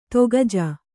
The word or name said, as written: ತಾಕೀಜು